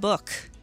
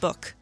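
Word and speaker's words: ML Buch: But one of the acts I went to see was ML Book. ML Bush? I think it's pronounced Book.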